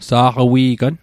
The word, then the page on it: saqawi'gan: Mi'gmaq